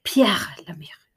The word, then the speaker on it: Pierre Lemaitre: Och då läste jag Målen på Moulin Rouge av Pierre Lemaitre. Nu har Veronica hjälpt mig med uttalet. Pierre Lemaitre.